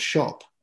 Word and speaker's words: SCHOP: And then came up with this business that we have now called Shop. It's spelled SCHOP for the benefit of the listeners.